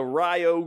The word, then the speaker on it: Rio: There's a road here in Charlottesville spelled R-I-O. How do you say R-I-O? – Rio. – Wrong. It's Rio.